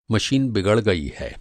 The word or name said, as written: बिगड़ना